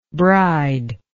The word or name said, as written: bridelike